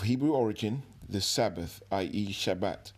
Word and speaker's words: Shabbat: So the Greek word is from the original Hebrew word that is pronounced Shabbat.